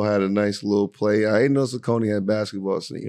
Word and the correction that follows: Saucony: Yeah, and like, it's funny because it's actually Saucony.